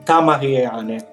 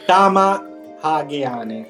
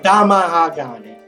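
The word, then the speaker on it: tamahagane: Eh, ne apre il tappo e vedete che c'è del tamariane. Del... Che tamahageane, mi sembra si pronuncia.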